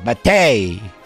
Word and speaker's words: mate: Yerba mate. Mate. – Even though it's spelled mate.